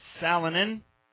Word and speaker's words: Salonen: That's Salonen.